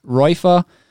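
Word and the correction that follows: Reifer: I'm pretty sure the guy's name is Raymond Reefer. Sorry. That probably makes it a bit more offensive, but I'm pretty sure it is how you pronounce it.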